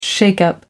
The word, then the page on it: shake up: American English